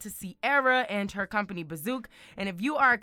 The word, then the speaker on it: Bizook: And it's spelled B-I-Z-O-O-K. That's B-I-Z-O-O-K, Bazook.